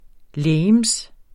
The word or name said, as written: legeme